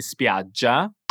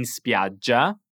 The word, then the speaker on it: spiaggia: Per andare in spiaggia? per andare in spiaggia. – Attenzione alla pronuncia della parola spiaggia. Dobbiamo pronunciare una G morbida ma doppia.